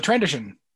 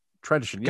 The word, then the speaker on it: Trandoshan: Is it Tadoshian? Is that it? – Oh, the Trandoshan. – Trandoshan. Yeah, yeah, yeah.